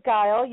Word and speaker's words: Geil: Pam Geil. I'm sorry. Am I pronouncing your name correctly? – It is Geil. You got it correct.